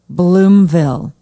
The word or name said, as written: bloomville